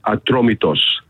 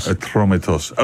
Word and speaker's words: Atromitos: And the Greek name is Atromitos. – Atromitos.